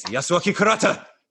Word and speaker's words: Yasuaki Kurata: Yasuaki, right? – Yes, Yasuaki Kurata.